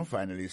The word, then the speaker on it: finally: Finally, c'est F-I-N-A-L-L-Y, finally. T'as des prononciations, oui? Finally. Accent tombé sous premier syllabe là. Finally. F-I-N-A-L-L-Y, ça veut dire en bon créole, enfin, séraphin, pied fin, manger à réussir, arriver sous table là.